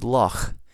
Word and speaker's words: loch: So a lot of you are familiar with how the Scots pronounce the word for their word for lake, which is loch, and it's a guttural CH.